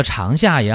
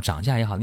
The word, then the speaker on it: 长夏: 这个长夏也好，长夏也好，你怎么读都可以啊。你读长夏比较简单。